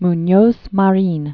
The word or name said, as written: Munoz Marin